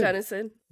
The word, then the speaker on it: Gunnison: Gunnison.